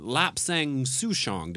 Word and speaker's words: Lapsang Souchong: But we have Kensington, Lapsang Souchong. Did I pronounce that correctly? – Yes, you did.